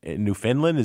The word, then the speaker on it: Newfoundland: Like Winnipeg, or I'm particularly fascinated with Newfoundland?